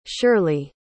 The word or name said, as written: shirley